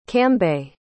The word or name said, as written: cambay